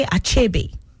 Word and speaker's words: Achebe: Okay, Achebe. Yes, that's the correct pronunciation.